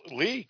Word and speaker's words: Leigh: I'm surprised you pronounce Lee correctly because it's spelled L-E-I-G-H.